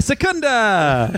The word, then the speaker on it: Secunda: It should delight all of you that the way that I figured out how to pronounce my own name was to say my cheer. And I went, ha, ha, ha, Secunda! And I was like, oh, that's how you do it.